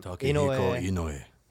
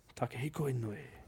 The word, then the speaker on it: Takehiko Inoue: Takehiko Inoue. – Takehiko Inoue.